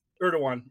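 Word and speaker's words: Erdoğan: Irigen is the head of Turkey. So he just Erdogan.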